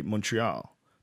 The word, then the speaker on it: Montreal: But it's like, you know, like Montreal is how we say it. But they say Montreal. They say Montreal. – Yeah. Montreal. – So really, you should say Montreal.